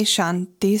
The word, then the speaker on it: Shanti: So this week's mantra is Om Shanti Shanti Shanti. And the word Shanti in Sanskrit is peace. Shanti is spelled S-H-A-N-T-I.